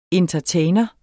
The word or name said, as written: entertainer